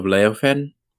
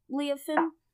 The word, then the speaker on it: Leofen: Have you ever been to the southern kingdom of Leofen? – I mean, probably.